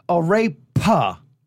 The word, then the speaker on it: arepa: So I just want to make sure we're saying it right. Arepa, right? – Arepa.